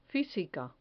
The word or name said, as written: fisica